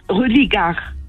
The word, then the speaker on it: Religare: Religar. – Ça s'écrit comment? – R-E-L-I... G-A-R-E.